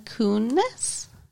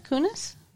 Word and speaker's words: Kunis: M-I-L-A-K-U-N-I-S. How would you pronounce that? – Mila Kunis? – Why would you put an ooh-ooh sound there? It's a U. – Because it sounds like it's a Greek word.